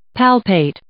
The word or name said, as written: palpate